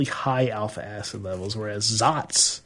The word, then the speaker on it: Saaz: The Zotz hop is the one that's spelled S-A-A-Z.